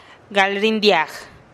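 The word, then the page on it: gal rindiag: Teotitlán del Valle Zapotec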